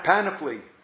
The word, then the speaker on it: panoply: we develop this Penelope, is that the word? I might be pronouncing it wrong. What? – Panoply.